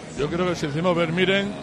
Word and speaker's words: Vermeeren: ¿Es Vermeeren o Vermeeren? – No lo sé. – Bueno, escucha, pronunciación oficial, Vermeeren. O sea, yo creo que Vermeeren nos vale.